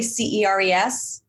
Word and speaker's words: Ceres: Where she teaches beginning to advanced levels of astrology and is published in the Ingress with her article, how do you say CERES? – Ceres.